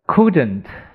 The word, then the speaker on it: couldn't: couldn't 等于 could not，啊，注意这个 l 没有发音的，这个 o u 发了，它短音发 u，可 u could could，嗯，couldn't 等于 could not，write。